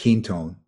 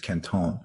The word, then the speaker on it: Quinton: Some call it Quinton, but the French pronunciation is Kenton.